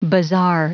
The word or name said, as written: bizarre